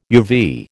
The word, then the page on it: UV: American English